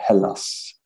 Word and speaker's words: Hellas: but it is Halas, correct? Or is it like, what is the proper way to say it for an English jerk like me? – You could say it that way. I say Hellas, but Hellas. Okay.